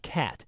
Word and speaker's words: qat: There are acceptable words like QAT, pronounced cat, and TRANQ, or trank.